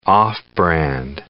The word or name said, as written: off-brand